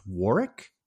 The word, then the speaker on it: Warwick: Warwick's grand, I believe his name is actually pronounced Warwick. The second W is silent.